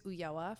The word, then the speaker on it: Ulloa: My username is @Gaby, G-A-B-Y. My last name is Ulloa, for no one who knows how to say it, U-L-L-O-A _.